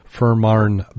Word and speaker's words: Fehmarn: F-E-H-M-A-R-N, Marn, Fehmarn.